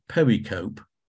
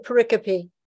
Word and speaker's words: pericope: David Bivin always said pericope. – That's it. That's the better way of describing what is a very Greek word, pericope.